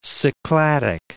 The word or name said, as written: Cycladic